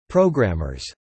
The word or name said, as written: programmers